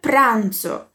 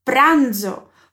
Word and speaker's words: pranzo: Como Alê estava preparando, estava fazendo seu almoço, ele diz: Mi preparo il pranzo. Preste atenção na pronúncia da letra Z. É como se a gente quase falasse um DZ, na verdade. Pranzo, pranzo.